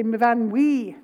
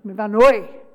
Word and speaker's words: Myfanwy: And most people, even in Wales, can't pronounce it. They say Myfanwy. No. Myfanwy.